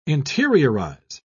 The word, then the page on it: interiorize: British English